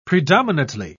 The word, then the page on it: predominately: British English